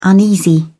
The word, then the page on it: uneasy: British English